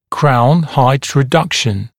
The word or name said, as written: crown height reduction